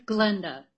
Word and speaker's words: Glenda: Oh, my name is Glenda, G-L-E-N-D-A, Jenkins, J-E-N-K-I-N-S.